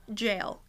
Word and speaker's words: Jael: It's spelled J-A-E-L, in case you're curious. It's not... Jael. It's Jael. There's a difference.